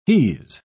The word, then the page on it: he's: British English